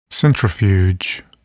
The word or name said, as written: centrifuge